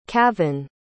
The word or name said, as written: kavon